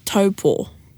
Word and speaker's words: Taupō: It's a rap about pronunciation of te reo Māori, and it's called Taupō, like T-A-O-R. T-O-E space P-A-W.